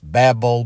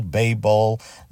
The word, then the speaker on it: Babel: I've heard both ways, Babel, Babel. Let us call the whole thing off. I've heard people pronounce it Babel, other people pronounce Babel. So use the pronunciation that you'd prefer.